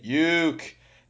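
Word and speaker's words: Youk: The first time I went, I was taught the correct way to say, Uke.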